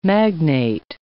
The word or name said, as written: magnate